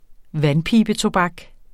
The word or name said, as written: vandpibetobak